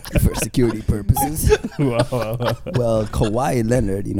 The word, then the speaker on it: Kawhi: Did I say that right? Kawhi or is it Kawhi? – It's Kawhi. Come on, man. – Kawhi.